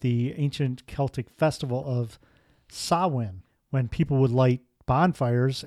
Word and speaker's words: Samhain: It's actually pronounced Samhain. – Oh, okay. – Completely wrong from what it looks like.